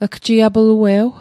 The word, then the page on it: gjiaplue'w: Mi'gmaq